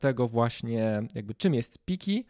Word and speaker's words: Piccy: jako współzałożycielkę Piki. E, Piki, e, które pisze się mm, trochę inaczej, czyli Mm, tak fonetycznie pizzy.